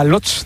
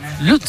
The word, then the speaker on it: Łódź: ¿Sabes cómo se pronuncia Łódź? Te lo voy a decir, es que es muy difícil. Łódź, venga. – Łódź. – Lutz. – Increíble, Guts, Guts, como si con W, Guts.